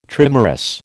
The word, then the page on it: trimerous: American English